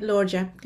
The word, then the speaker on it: Lorgia: You say it Lorgia, kind of like Georgia, but with an L.